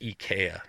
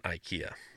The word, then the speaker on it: IKEA: And then he's from Sweden, and he says, it is definitely pronounced IKEA, not IKEA.